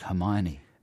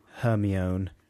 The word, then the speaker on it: Hermione: It like Hermione from Harry Potter. – Right, and that's another commonly mispronounced one. A lot of people going for Hermione and things like that.